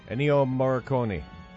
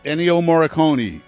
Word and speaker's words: Ennio Morricone: How do you pronounce this guy's name? I'm not Italian. – Ennio Morricone. – Ennio Morricone.